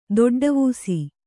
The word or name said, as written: ದೊಡ್ಡವೂಸಿ